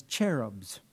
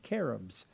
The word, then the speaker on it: cherubs: The first category of angels that the Bible identifies there in Genesis 3 are cherubs, sometimes mispronounced as cherubs. But in the Hebrew, the CH is the hard K sound, cherubs.